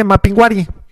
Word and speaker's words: Mapinguari: Ahí dice Mapinguari. – Dice Mapinguari. Ah, es que no tiene acento en la I.